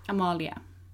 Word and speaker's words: Amalie: Well, I love how you said my name Amalia and then suddenly Amalie. – Because how you spell it is Amalie. So if they're looking you up. – Amalia. – No, but if they're typing it, it's with an E. So it's like Amalie. But if you say it, it's Amalia. – A-M-A-L-I-E.